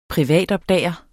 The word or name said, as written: privatopdager